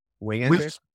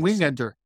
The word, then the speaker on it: Wingenter: Yeah, but yeah, but in terms of like the bullpen, Trey, how do you say his last name? Trey Winner, Wingenter. I've been yeah, saying Wingenter. Wingenter. Okay, same here.